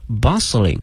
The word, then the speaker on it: bustling: 哎，这里面看一下这个海口哈，这个马路的前面跟了一个形容词叫 bustling, B-U-S-T, L-I-N-G, bustling, 同时注意一下这个 T 是不发音的，